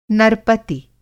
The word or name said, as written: नरपति